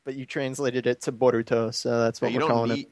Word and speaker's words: Boruto: It's supposed to be, like, Bolt. – Yeah, like Bolt. The Bolt. – Yeah, but it's translated into B-O-R-U-T-O.